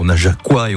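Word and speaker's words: Najacois: Depuis 20h, bon, on s'est pas mal baladé, même si on a offert un joli coup de projecteur au Najaquois et au Najaquoises. Bien comme ça hein, qu'on prononce. – Tout à fait.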